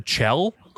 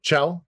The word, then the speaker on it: Chell: So they did add the co-op, which were instead of having humans, because Shell, am I saying that right? Shell? – I have always said Chell. – Chell. It's probably right. – It could be Shell, like short from Michelle. – It's C-H-E-L-L.